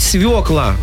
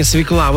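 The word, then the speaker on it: свёкла: что правильно говорить «свекла», а не «свекла». Вот это вот очень хорошо уже.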